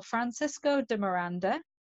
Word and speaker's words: Francisco de Miranda: So through her uncle James Barry, she was also kind of related or linked to a Venezuelan radical called General Francisco de Miranda. – Brilliant Spanish pronunciation there. It was like it was in your mother tongue.